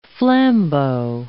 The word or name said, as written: flambeau